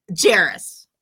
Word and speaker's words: Jarros: Jarros like Harris. – I screwed it up. – That's all right. Most people pronounce it the way you do because that's how it looks.